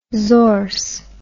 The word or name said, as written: zorse